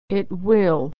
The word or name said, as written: it will